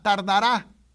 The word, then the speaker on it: tardará: Habacuc dice, aunque la visión tardara, con acento en la tardará, aún por un tiempo.